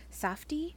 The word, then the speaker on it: Safdie: Safdie? – That sounds right.